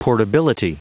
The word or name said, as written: portability